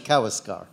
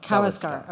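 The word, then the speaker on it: Kawésqar: I'm always bad. The accent is on the middle. I know where the accent is, but I still have trouble saying it right. But it's Kaweskar. – Kaweskar, okay, yeah, sorry.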